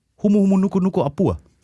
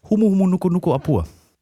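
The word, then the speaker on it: Humuhumunukunukuapua'a: Humu humu nuku nuku apua. – Du mich ja auch. – War das richtig betont? – Das war richtig betont und war auch richtig ausgesprochen und es ist kein Scherz.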